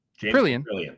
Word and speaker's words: Caprielian: James Caprielian got released. – Caprielian. – I know that guy. He's like my buddy. I don't know why I didn't say that right.